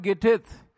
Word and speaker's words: Gittith: Now this word Gittith, that's how it is pronounced.